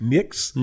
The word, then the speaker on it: Nyx: how do you pronounce it? NYX. Nix? – Nix.